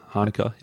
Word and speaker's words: Hanukkah: Am I pronouncing that correctly? – Yeah, close enough. Hanukkah, yeah.